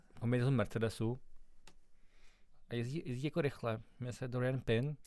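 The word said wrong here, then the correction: Doriane Pin: Pozor, tady bych tě opravil jenom, už jsem dostal dvakrát upozornění, kterého samozřejmě vážím, na výslovnost téhle závodnice francouzské, Dorian Pin.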